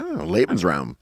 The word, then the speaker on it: Lebensraum: Yeah, Lebensraum. – Say that, Jonah. Good pronunciation of the German.